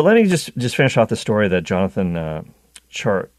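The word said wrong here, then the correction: Tjarks: wrote for The Ringer. Jonathan Charks, rather, excuse me.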